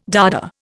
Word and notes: Dada: Indian

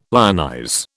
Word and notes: lionize: British English

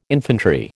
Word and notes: infantry: American English

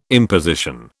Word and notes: imposition: British English

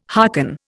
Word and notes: harken: Indian

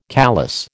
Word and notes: callus: American English

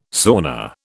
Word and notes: sauna: British English

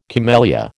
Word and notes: camellia: American English

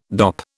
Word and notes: dop: British English

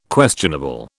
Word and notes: questionable: British English

